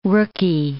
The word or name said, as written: rooky